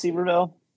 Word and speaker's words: Sevierville: of connects Gatlinburg, Pigeon Forge, in Sevierville. I think it's pronounced Sevierville, uh, and it's called Parkway, and it's uh, you know it's you know bigger bigger highway in some places than others.